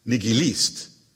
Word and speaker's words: nihilist: In Russian, it's pronounced nihilist, with accent on the last syllable. – My observation on nihilist was prompted by your having corrected my pronunciation, apparently under the impression that the first syllable in English was pronounced ni.